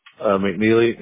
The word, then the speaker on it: McNeely: McNeely, M-C-N-E-E-L-Y.